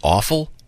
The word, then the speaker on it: offal: Is it pronounced awful? – Mm-hmm. – O-F-F-A-L?